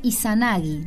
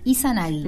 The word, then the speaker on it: Izanagi: Amaterasu emergió del ojo izquierdo de su padre llamado Izanagi. Lo dije mal. Izanagi.